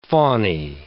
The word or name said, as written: faunae